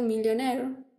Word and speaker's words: Millionaire: En 1990, Chanakya, 2006, The Killer, 2008, Slumdog Millionaire, o millonario, ¿cómo se dice? ¿Millonario o millonario? – Millionaire. – Millionaire. Por Pero se escribe Naire.